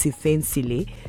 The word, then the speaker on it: Siphesihle: It is S, you know how South Africans spell their name now, S-I-P-H-E-S-I-H-L-E, that's Sifen Sele. So the shorting form is the Sele, which is now stylized as C-H-L-E-Y, which makes it Englishized, but the pronunciation is still South African.